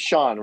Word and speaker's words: Sean: Well, my first name is Sean, right? Spelled S-E-A-N, which apparently, despite the existence of Sean Connery, nobody can also seem to pronounce.